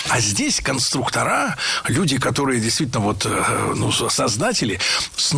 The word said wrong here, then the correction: конструкторы: Конструктора, конструкторов, это неверно. В слове «конструктор» ударение должно сохраняться на втором слоге, на «у». Поэтому конструкторы, конструкторов.